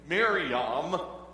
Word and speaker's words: Miriam: Miriam. Let me say it again. Miriam really is the way it's pronounced.